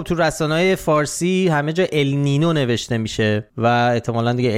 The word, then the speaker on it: ال نینیو: به هر حال اینو همین اول بگیم که اگه دیدید یه بار میگیم ال نینو یه بار میگیم ال نینیو، علتش اینه، ولی تلفظ درست اگه میخواین ال نینیو، ولی همه جا تو فارسی بچرخید کسی چی نمیشه ال نینیو، همه جا نوشتن ال نینو.